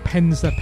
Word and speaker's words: Penza: Let me pronounce it correctly. Penza, P-E-N-Z-A.